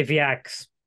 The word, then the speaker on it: VEAX: Was oh, it Vex? How do you pronounce this? So I normally say Vex,